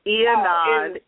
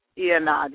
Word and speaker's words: Y'Anad: Ianad is my first name. – Ianad. – But it's very different. Ianad, yes.